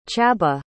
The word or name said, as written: Chabah